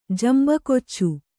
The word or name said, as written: ಜಂಬಕೊಚ್ಚು